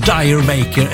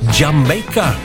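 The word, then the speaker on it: D'yer Mak'er: Ja, och det får de också i den här låten, Dire Maker, eller Jamaica, som den ska uttalas egentligen.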